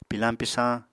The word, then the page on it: pilañ-pisañ: Apatani